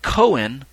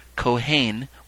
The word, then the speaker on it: kohen: The Hebrew term kohen, spelled with a K-O-H-E-N, which is also spelled sometimes with a C, C-O-H-E-N, kohen, or kohen, as it's pronounced in different ways, means priest.